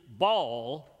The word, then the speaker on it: Baal: The proper pronunciation is Baal.